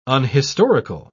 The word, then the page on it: unhistorical: British English